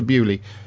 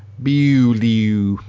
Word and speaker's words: Beaulieu: Time Warp Bessie brings Doctor Who to Bewley. Spelled B-E-A-U-L-I-E-U. So how would the Americans say that? Bewley. Bewley, I suppose you'd say. – Bowler. – Yeah. – Bewley. Bewley in Hampshire.